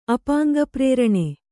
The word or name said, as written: ಅಪಾಂಗಪ್ರೇರಣೆ